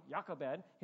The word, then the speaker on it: Jochebed: In Exodus chapter 6, verse 20, it says, Amram took his wife Jochebed, or pronounced Jochebed, like with a Y, Jochebed, his father's sister, and she bore him Aaron and Moses, the years of the life of Amram being 137 years.